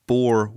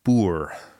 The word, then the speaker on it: Boer: I called it the Boer War. – Okay. – Right. – And it's actually pronounced Boer.